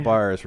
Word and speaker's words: Bars: Are you confident in your pronunciation of Bars? – No. I think like I'm going to screw it all. I'm a very reader, not speaker guy. – Got Bars right.